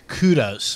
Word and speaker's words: Kudos: Kudos.